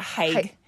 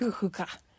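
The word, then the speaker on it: hygge: It's people pronounce it haig, but it's actually pronounced like hookah.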